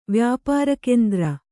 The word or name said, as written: ವ್ಯಾಪಾರಕೆಂದ್ರ